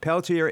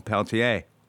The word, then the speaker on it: Peltier: Peltier is an anglicized pronunciation of the original Peltier.